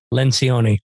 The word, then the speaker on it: Lencioni: Patrick Lencioni.